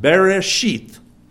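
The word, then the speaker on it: bereshit (בראשית): The word beginning in the Hebrew is actually the word what would be pronounced bereshit.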